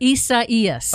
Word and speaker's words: Isaias: And now, Tropical Storm Isaias, I think that's how you say it. – Isaias. – Oh, I wasn't even close.